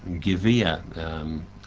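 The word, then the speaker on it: Gviyah (גויה): How it's pronounced? Gviyah.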